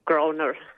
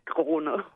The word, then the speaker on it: Groner: Groner. – Groner, well, yeah. Sorry. – If you want to pronounce it with a German accent, it's Groner.